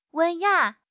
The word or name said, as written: 文雅